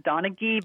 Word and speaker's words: Donaghey: And my last name is said Donaghey, but nobody ever gets it right.